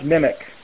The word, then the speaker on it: MMIC: A monolithic microwave integrated circuit, or an MMIC, it's pronounced MIMIC in the trade,